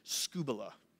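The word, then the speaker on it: scubala (σκύβαλα): Interesting thing about this word is in the Greek, it is pronounced scubala. Can you say that to your neighbor? Scubala. Scubala. Has nothing to do with diving in the ocean.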